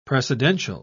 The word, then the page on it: precedential: British English